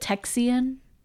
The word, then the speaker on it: Texian: Now, this is spelled T-E-X-I-A-N. Am I saying Texian? I wonder if it's a mixture of Mexican and Texans. – It is, yeah.